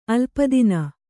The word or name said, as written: ಅಲ್ಪತನು